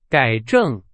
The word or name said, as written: 改正